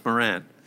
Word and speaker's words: Morant: Morant.